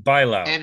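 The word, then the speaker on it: Bailão: They fucking hate Toronto, which is, of course, why if we ever get, Anna Bailao, is that how we pronounce her name? – Bailao.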